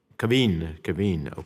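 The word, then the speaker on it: Kvien: I'll begin with Ms. Kavin. Is that the correct pronunciation? Kavin, okay, I'm sorry, who has been nominated to be the ambassador to Armenia.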